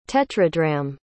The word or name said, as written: tetradrachm